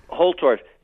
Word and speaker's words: Holtorf: Now, if Richard, how do you pronounce it, Holbrook? – Holtorf.